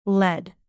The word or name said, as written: led